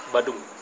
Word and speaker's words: Badung: Badang? – Badung. – Badung. – Yeah. – In Badung. Did I say that right? – Yes.